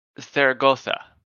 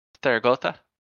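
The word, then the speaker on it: Zaragoza: Theragotha. – Theragotha. – It's that sounds a bit weird to say. – It's Saragossa, but with the pronunciation of Spanish, the S's are like sort of TH's. Theragotha.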